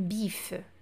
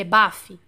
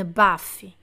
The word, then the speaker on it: baffe: Je te donne une bif. Não é bife, é bafe. Je te donne une bafe. B-A-F-F-E, se eu não estou enganada, tá?